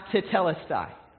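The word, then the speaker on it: tetelestai (τετέλεσται): The word is pronounced tetelestai.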